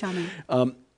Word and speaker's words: Xiamen: if I pronounce mm-hmm. the name correctly. – I think it is Xiamen.